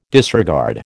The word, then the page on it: disregard: American English